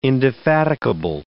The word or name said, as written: indefatigable